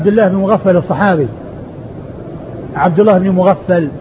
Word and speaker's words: مغفل: شخص واحد هو مغفل وهو والد عبد الله الصحابي, الصحابي عبد الله بن مغفل والده مغفل بالغين والفاء المشددة.